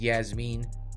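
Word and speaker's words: Iasmin: I'm like, I-A-S, that's weird. But it is, it's pronounced Yasmin Lucindo versus Brogan Walker.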